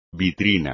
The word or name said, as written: vitrina